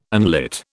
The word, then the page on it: unlit: British English